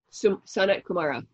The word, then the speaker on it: Sanat Kumara: There is, and if I'm pronouncing this incorrectly, tell me, is it Sanat Kumari? – Sanat Kumara. – Mm-hmm.